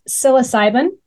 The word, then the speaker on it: psilocybin: So talking about, and please help me because I Googled this even and hit pronounce, psilocybin. Am I pronouncing that correctly? – That's correct. Yes.